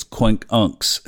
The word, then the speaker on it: quincunx: So the moon today is inconjunct Pluto, or we could say quincunx, or as Steve Forrest wrote in the Endless Sky audiobook, it's pronounced quincunx. It's not quincunx. It's Quink Unks, if you want to say it, pronounce it correctly.